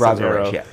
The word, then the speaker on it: Ra's al Ghul: It's Ra's al Ghul, yeah.